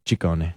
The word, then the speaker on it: Ciccone: Well, in Italian, it works the other way. So the double C's are hard C's and the singular C's are soft C's. – So Ciccione. – Ciccone. – Ciccone. Madonna Ciccone. God, why'd she drop that? That's good. It's Ciccone. Anyway, it's Freedom Fridays.